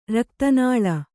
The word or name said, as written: ರಕ್ತನಾಳ